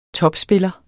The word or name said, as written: topspiller